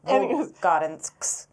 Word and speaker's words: Volgodonsk: Volgodonsk. – Volgodonsk. I'm so sorry. – We slaughtered that. It was terrible. Volgodonsk.